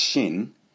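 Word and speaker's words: Xin (心): As you've heard me say before, the Chinese character pronounced Xin means both heart and mind is just one thing.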